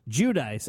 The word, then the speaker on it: Giudice: Speaking of manhood, the Real Housewives stars, Joe and Teresa, and Giudice is how you pronounce their name. I learned that from watching Wendy Williams. Giudice, sort of like the coffee shop.